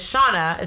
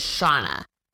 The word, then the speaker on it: Shana: my name is Shauna, it's spelled S-H-A-N-A. It sounds like Shanna or Shayna, but my name is Shauna.